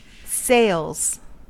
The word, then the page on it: sails: American English